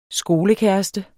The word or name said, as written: skolekæreste